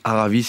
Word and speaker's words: Aravis: Vous prononcez pas le S. Hein, non. J'évite ouais. Depuis l'émission, on a dit Salers. Hein. – Oui. – Voilà, maintenant j'ai fait gaffe à mes S. – Ça s'écrit Aravis, mais effectivement ça se prononce Aravis, sinon on passe vraiment pour un sale parisien.